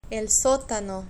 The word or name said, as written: el sotano